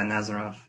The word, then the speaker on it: Nazarov: Yeah, Nazarov.